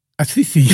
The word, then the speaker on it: Assisi: Ja, en die datum die is dus gekozen omdat 4 oktober de sterfdag is van de heilige Franciscus van Assisi. Dat kan jij denk ik niet uitspreken. – Assisi.